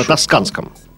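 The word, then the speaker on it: Toscana: Президент Путин вечером 1 апреля инкогнито посетил знаменитый ресторан в, а, в Порт-Эрколе на Танканском побережье. Я правильно произношу? На Тосканском.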